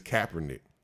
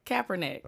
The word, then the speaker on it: Kaepernick: And it's Kaepernick. – Kaepernick.